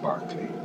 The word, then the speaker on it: Berkeley: I believe it's spelled Berkeley, isn't it? It's pronounced Berkeley.